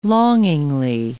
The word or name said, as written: longingly